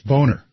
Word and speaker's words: Boehner: And you're pronouncing it wrong. It's Boehner.